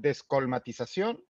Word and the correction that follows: descolmatación: ¿es? – Descolmatación. – descolmatación en un lugar que va a volver a,